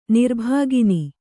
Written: ನಿರ್ಭುಗ್ನ

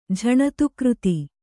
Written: ಝಣತುಕೃತಿ

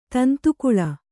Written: ತಂತುಕುಳ

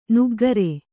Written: ನಗೆಗಾರ

